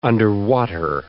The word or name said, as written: underwater